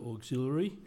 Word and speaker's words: auxiliary: And mindfully, it is the hospital auxiliary. – Yep. – Which you can remember because it rhymes with capillary.